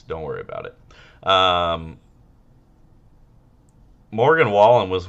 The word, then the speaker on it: Wallen: How do you say that? That cunt's name? Wallen? – I think it's Wallen, but I'm pretty sure it was Jason Aldean that did the, like, try that in a small town.